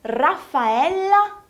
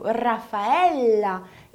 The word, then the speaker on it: Raffaella: Esse L foi pronunciado do mesmo jeito que nós falaríamos o nome Raffaella no Brasil. A única diferença é que em italiano se escreve com dois L's e por isso esse som do L vai ser um pouquinho mais longo, Raffaella, e não Raffaella.